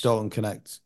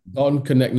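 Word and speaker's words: Dalton Knecht: Dalton Connect. – Dalton Connect.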